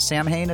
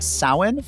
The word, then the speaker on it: Samhain: It's not pronounced Samhain. It's pronounced Samhain for the earth-based religionists among us.